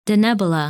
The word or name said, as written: Denebola